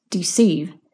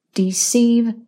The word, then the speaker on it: deceive: The second word is deceive. Deceive rhymes with receive. Repeat after me. Deceive.